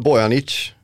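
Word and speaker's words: Bojanić: Intressant tycker jag med Bojanic. Är det rätt uttal? – Ja.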